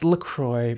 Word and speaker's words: LaCroix: It's LaCroix, bro.